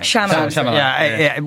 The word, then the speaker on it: Shyamalan: Night Shyamalan, I can't say his name, Shyamalan.